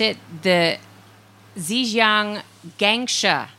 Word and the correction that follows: Guangsha: Lions. – Guangsha. Yeah.